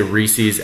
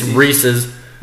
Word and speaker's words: Reese's: I know I say Reese's and it's wrong. It's Reese's.